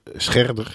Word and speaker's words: Scherder: Ja, eh, dat is het Erik Schredderhuis. Scherder, ik moest de naam goed uitspreken.